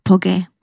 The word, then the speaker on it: Pogge: Army called Caroline Poguet, spelled P-O-G-G-E. It's pronounced Poguet. Well, and another serviceman called Sergeant William Von Zehol, V-O-N, space Z-E-H-L-E, who in his hometown is a firefighter from Connecticut.